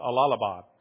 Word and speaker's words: Allahabad: Allahabad, spelled like Allah and then Abad.